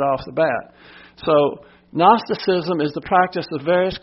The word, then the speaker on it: Gnosticism: And then we have Gnosticism. The G is silent.